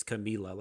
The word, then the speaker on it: Camila: But there's an interview of her like on the Tonight Show or something, and she's like, it's Camila, like she pronounces it. – Yeah. – So yeah, no, and that makes sense. Like Camila. No, absolutely makes sense with the one L that it would be Camila Cabello.